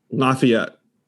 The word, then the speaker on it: Lafayette: Lafayette. Okay. – I thought he said Lafaya yeah, is what he said initially.